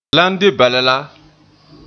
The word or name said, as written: lan